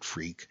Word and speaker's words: Freke: I kind of prefer to say Freka, but uh, he pronounces it Freak, D.M.